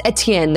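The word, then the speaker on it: Étienne: Oh, Etienne. Is that how you pronounce it? – If it's French. – It's French. It's Etienne, then.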